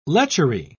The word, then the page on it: lechery: British English